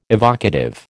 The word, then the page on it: evocative: American English